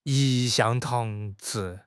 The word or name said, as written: 一向